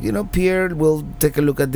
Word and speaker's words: Pierre: I was going to say Pierre, South Dakota, but I've been reliably informed it is pronounced Pierre, or something similar to that.